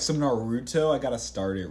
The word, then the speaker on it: Naruto: No, it's Naruto, yeah. – Okay, good. Like, emphasis on the ooh.